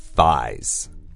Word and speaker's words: thigh: 对，因为它的这个拼法是 T-H-I-G-H. – Yeah, don't worry about that G-H. It's just like I.